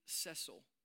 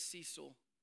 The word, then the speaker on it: Cecil: A few years ago, back in 2015, anybody remember a beloved lion named Cecil? And I know some of you will say, it's Cecil, fight me. It's Cecil. At least that's how they were pronouncing it.